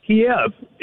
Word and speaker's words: Kyiv: Every time you hear the capital of Ukraine, they're calling it Kyiv instead of Kiev. – Yeah. – Kiev is the way it's pronounced.